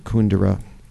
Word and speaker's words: Kundera: You know, I was reading Milan Kundera, or as he pronounces it, Kundera.